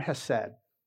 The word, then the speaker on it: hesed: This idea of unfailing love being one of the attendants, as I mentioned, that's the word hesed. It's a Hebrew word, so it should be pronounced with a lot more phlegm in your throat, but I'll just say hesed, right?